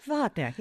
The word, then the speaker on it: Wagner: Wagner, yes.